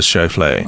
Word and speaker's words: Schauffele: Xander Schauffele uses one, I think, doesn't Schauffele. – He? Schauffele. – Yes. – Is that how you say it? – Xander Schauffele. – Oh, okay.